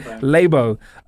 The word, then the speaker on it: Labo: Is it Labo? – I said Labo.